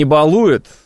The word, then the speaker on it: балуют: Вообще, правильно говорить, не балуют.